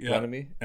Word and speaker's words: Bieniemy: with Andy Reid and Eric – I can't pronounce his last name. Yeah, Bieniemy. Bieniemy. Bieniemy, yeah.